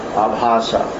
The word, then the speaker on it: Abhasa: When the clearing stage of chanting is there, what's as an S, Abhasa, Nam Abhasa. – Long A's. – Abhasa.